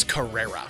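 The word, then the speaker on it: Carrere: Tia Carreri, did I say that right? – I think it's Carrera.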